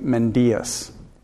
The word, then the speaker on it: Mendias: I just found out on Sunday, Rick, the other Rick, with the M, said, Rick Hansen said, he says his name Mendias. I've been mispronouncing it all along.